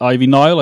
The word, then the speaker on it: Ivy Nile: She was defending the championship against Ivy Nile. I believe I'm saying that right. – Yeah, uh, she was. – No, you you were saying it pr- uh, to perfection. I couldn't have said it better.